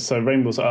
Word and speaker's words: Rainn Wilson: I knew that, I'm not sure exactly if this is how you pronounce his name, but is it Rainn Wilson or... Rainn Wilson.